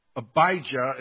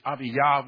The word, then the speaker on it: Abijah: I should notice that his son's name is Abijah, is how we pronounce it, I suppose, in English. Abiyah would be maybe the better way to pronounce it in Hebrew.